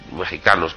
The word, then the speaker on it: mexicanos: Pero mexicanos se, se pronuncia con J, pero se escribe con X no digáis mexicanos, ni escribáis mexicano con J.